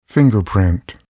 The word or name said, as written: fingerprint